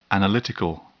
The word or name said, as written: analytical